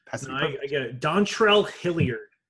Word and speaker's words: Hilliard: Yeah, those can you spell Hilliard? – Hill. – Like Ike Hilliard? – Yeah, like Ike Hilliard. – Hill-Iard.